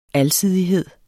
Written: alsidighed

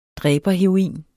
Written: dræberheroin

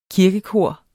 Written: kirkekor

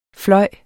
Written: flyve

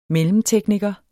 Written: mellemtekniker

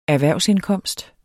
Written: erhvervsindkomst

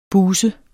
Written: buse

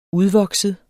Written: udvokset

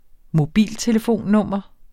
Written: mobiltelefonnummer

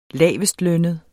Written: lavestlønnet

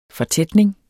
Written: fortætning

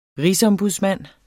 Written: RIGSOMBUDSMANDEN

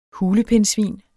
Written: hulepindsvin

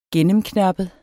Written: gennemknappet

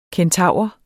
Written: kentaur

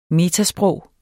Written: metasprog

